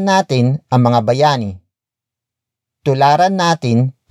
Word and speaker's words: natin: The next word is natin. Natin. Natin. Natin is spelled as N-A-T-I-N.